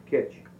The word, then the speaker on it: Kicz: Now, since that time, we now have John Kitch, and that's spelled K-I-C-Z, but pronounced Kitch, and he is our civil man at this time.